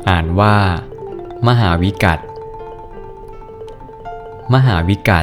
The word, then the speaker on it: มหาวิกัฏ: สวัสดีครับพบกับรายการคำวัดวันนี้ขอเสนอคำว่ามหาวิกัต